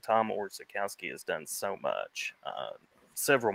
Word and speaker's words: Orzechowski: Orzechowski.